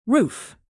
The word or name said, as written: roof